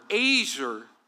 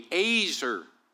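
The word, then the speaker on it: azer (עזר): It's spelled E-Z-E-R. That would be how we would phonetically spell it. But we would pronounce it, that E, like an A, a long A, azer. But it's spelled like easier, which I find interesting.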